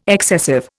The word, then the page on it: excessive: Indian